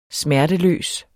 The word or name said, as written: smerteløs